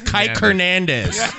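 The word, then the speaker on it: Kiké: You have to put the accent on the E when you spell Kike online. Because then it says Kike Hernandez.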